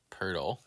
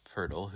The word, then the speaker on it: Poeltl: Purtle. – Which is named after... – Jakob Poeltl, who's an NBA player, and it's spelled P-O-E-L-T-L.